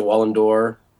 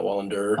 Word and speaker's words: Wallander: Yes, I think you guys pronounce it Wallandor, not Wallander.